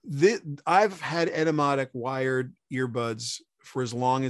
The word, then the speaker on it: Etymotic: it's e-t-y M O T I C, Etymotic.